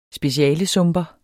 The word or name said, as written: specialesumper